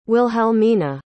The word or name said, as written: wilhelmina